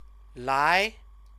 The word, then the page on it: lai: Chinese